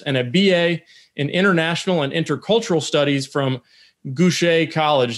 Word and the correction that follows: Goucher: Did I pronounce that correctly, Dante? – Goucher College. – Goucher. I went French on you.